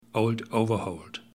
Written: old overholt

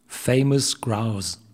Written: Famous Grouse

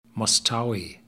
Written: Mosstowie

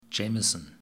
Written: Jameson

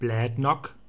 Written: Bladnoch

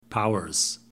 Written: powers

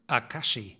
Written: akashi

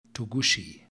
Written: togouchi

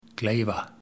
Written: Glayva

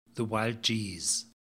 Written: the wild geese

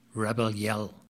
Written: rebell yell

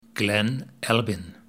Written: Glen Albyn

